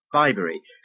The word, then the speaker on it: Bibury: Bybury. – It's right here, on the A433, between Burford and Cirencester. Just south of here. You see? – You mean Bibury? – Well, we pronounce it Bybury.